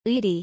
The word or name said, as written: 의리